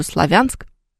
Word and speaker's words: Славянск: Да как угодно они там могут говорить, но он же Славянск. Ну, если мы откроем словарь, мы обнаружим там этот населенный пункт, который с ударением на букву «Я».